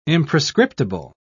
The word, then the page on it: imprescriptible: British English